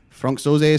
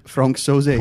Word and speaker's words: Sauzée: Franck Sauzée is correct. Oh, sorry. Say it again. Sorry? Pronounce it again for me. Franck Sauzée.